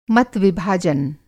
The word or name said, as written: मत-विभाजन